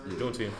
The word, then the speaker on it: Juneteenth: How do you pronounce it again? Juneteenth? – Juneteenth, yeah. You got it right.